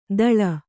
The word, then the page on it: ದಳ: daḷa